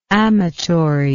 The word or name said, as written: amatorially